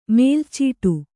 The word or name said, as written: ಮೇಲುಚೀಟಿ